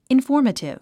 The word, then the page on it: informative: infɔ́ː r mətiv; インフォーマティブ